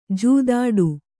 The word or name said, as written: ಜೂದಾಡಿ